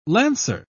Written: lancer

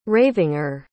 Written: ravinger

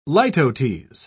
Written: litotes

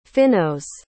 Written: Phinneaus

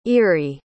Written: irie

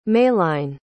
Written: maline

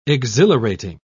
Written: exhilarating

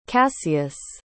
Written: cassius

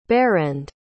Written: berend

